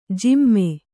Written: ಜಿಮ್ಮೆ

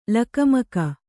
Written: ಲಕುಮುಕಿ

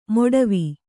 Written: ಮೊಡವೆ

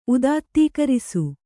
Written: ಉದಾತ್ತೀಕರಿಸು

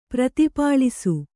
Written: ಪ್ರತಿಪಾಲಿಸು

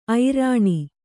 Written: ಐರಣಿ

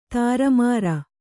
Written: ದುರ್ಮಾರಿ

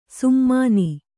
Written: ಸುಮ್ಮಾನಿ

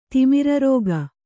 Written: ತಿಮಿರರೋಗ

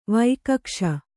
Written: ವೈಕಕ್ಷ

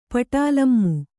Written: ಪಟಾಲಮು

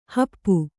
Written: ಹಪ್ಪು

